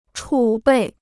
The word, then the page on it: 储备: chǔ bèi